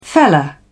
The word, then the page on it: feller: British English